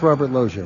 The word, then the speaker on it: Robert Loggia: L-O-G-G-I-A. Yeah. That is how you pronounce Robert Loggia, right? – Yeah.